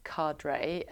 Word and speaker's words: Qadre: Qadre, yes, we just spoke about it, it's pronounced Qadre,